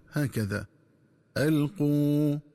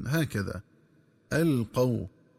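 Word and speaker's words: أَلْقُوا: فأما الفعل الأول فهو ألقوا، في قال ألقوا، فيقرأ بضم القاف وواو مدية هكذا ألقوا، ومن الخطأ قراءته بفتح القاف وواو لينة هكذا ألقوا.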